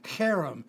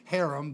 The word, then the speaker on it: cherem: The Hebrew word is cherem, C-H-E-R-E-M, C-H-E-R-E-M, not harem, but cherem, a C-H sound like in church, only we pronounce it as a K.